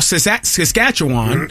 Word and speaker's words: Saskatchewan: But what I'm saying is in that person in Moose Jaw, Saskatchewan, did I say it right? – Bless you.